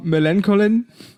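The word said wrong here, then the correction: Millencolin: Millen Colin. – Millen Colin, klingt wie so ein Doppelname aus Millen und Colin.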